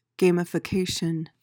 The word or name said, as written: gamification